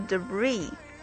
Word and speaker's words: debris: 再看后边这个 debris 这个词，注意它的重音是在后面，而且这个 s 是不发音的，它是表示碎片残骸的意思。